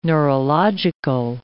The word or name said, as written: neurological